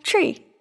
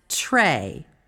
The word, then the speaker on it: Tre: I drew tree. – Tree? Are you sure that's what it says? – Yep. T-R-E. Tree. – I think that's actually pronounced Trey.